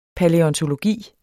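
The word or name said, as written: PALÆONTOLOGIEN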